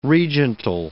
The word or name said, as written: regental